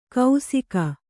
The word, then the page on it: ಕೌಸಿಕ: kausika